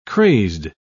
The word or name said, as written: crazed